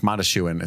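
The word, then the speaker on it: Mondoshawan: Yeah, it's like Mondashuan is how they pronounce it, different than it looks.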